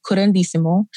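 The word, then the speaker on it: curanderismo: Can you help me pronounce it and also help me define it for people? – Yeah. So it's called curandísimo.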